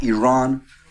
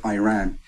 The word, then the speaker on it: Iran: You say you support the Iranian people, but you're pronouncing our country's name wrong. Our country is named Iran, not Iran.